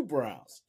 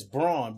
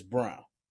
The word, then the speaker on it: Brown: They got two Browns. Yeah. One is Braun, but they said it's Brown. – Pronounced Brown, yeah.